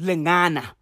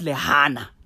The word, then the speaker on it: Lingana: I mispronounced Lingana. I said Lihana.